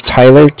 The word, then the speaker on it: Tyler: Okay, my name is Tyler, T-Y-L-E-R. And I'm calling with Cardiff. It's spelled C-A-R-D-I-F-F, as in Frank.